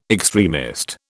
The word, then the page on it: extremist: British English